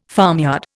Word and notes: farmyard: Indian

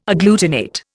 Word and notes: agglutinate: Indian

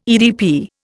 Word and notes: EDP: Indian

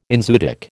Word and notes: enzootic: American English

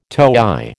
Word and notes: toey: American English

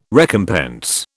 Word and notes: recompense: British English

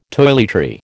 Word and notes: toiletry: American English

